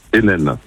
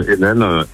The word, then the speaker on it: Henine: Vincent Hennen, avec un H-E-N-I-N-E. Ça se prononce comme Hennen, N-N, mais on peut l'écrire Heineken aussi.